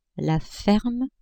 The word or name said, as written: ferme